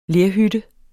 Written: lerhytte

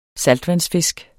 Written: saltvandsfisk

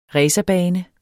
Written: racerbane

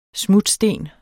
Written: smutsten

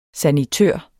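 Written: SANITØR

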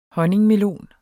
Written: honningmelon

